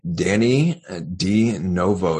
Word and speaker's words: Dannie De Novo: And today I have with me Dannie De Novo.